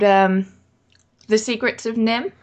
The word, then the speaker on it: NIMH: But it's based on a book called Mrs. Frisbee and the Rats of Nim. And Nim is spelled N-I-M-H, and it stands for National Institute of Mental Health.